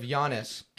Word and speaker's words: Giannis: I did see a video of Giannis.